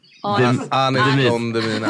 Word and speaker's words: Anis Don Demina: Alltså, för lyssnarnas skull så är Danis Don Demina. – Anis Don Demina. – Anis Don Demina. Det är så dåligt. Danis. Nej, Anis Don Demina.